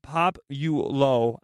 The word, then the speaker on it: Popolo: Popolo. I say it wrong. I always say it wrong. But Matt just pulled it up for us. It's called Pop You Low. I was so far off, Matthew. – You got the P and the O.